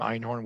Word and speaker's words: Einhorn: And my last name, Einhorn, which means unicorn in German.